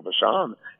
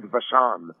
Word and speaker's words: Vachon: And it's funny because in French, pig rhymes with Vachon.